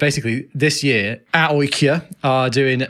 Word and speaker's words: AIK: And they said, basically, AIK in Sweden, or AOIK, as we learned they were pronounced when we were there.